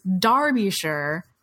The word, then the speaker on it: Derbyshire: And it is Derbyshire and Hertfordshire, though they're E's.